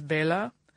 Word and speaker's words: Bela: It's because when I pronounce it correctly in the Hungarian language, which is Bela, I sound like the count from Sesame Street.